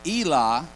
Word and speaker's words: Elah: Elah, is how it's pronounced,